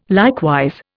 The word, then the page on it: likewise: Indian